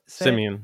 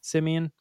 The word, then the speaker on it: Simien: It's Justin Simien, right? Is that how you say it? – Simien, yeah. – Simien.